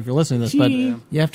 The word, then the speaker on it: Cheney: The town where the actual campus is located is it's just spelled Cheney, which how would you pronounce that? – Cheney? – Yeah. It's pronounced Cheney.